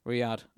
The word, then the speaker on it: Riyadh: I never pronounced the Saudi Arabian's capital correctly, so I'm Riyadh. Oh yeah, there we go.